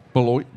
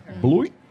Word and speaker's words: Beloit: Beloit. – I think you said it wrong. – Beloit. – Beloit. – Beloit. – It sounds like water. – It plops into water. Beloit.